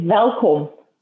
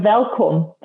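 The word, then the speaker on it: Welkom: Well, just to get the pronunciation right, it's Welcome. Oh. So you need that guttural Afrikaans. It's deep in the heart of the Free State. You go Welcome.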